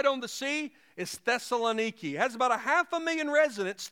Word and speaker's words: Thessaloniki: Unlike some biblical cities that are ancient ruins, the city of Thessalonica, although it's pronounced today in modern-day Greece, Thessaloniki, the city still exists.